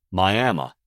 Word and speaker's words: Miami: And by far the most popular town mispronounced along the Mother Road is Miami, spelled just like the city in Florida, but pronounced Miami, and that's in Oklahoma.